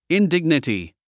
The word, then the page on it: indignity: indíɡnəti; インディグネティ